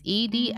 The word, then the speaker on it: Edi: Do you say his name Edi or Edi? It's Edi Gathegi.